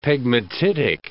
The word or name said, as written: pegmatitic